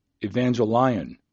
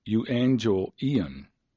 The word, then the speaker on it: euangelion: To me, it looks like evangelion, but that's not how it's pronounced at all. It's euangelion, and it's Strong's number 2098.